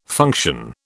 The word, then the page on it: function: British English